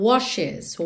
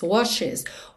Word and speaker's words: washes: Olha que interessante, nessa palavra washes, esse é pronunciado, ok? Até porque ficaria muito difícil você ir de um SH para um S, washes, né? Ficaria um pouco confuso. Então, washes, washes. O E realmente faz a ponte entre o SH e o S.